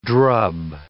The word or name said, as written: drub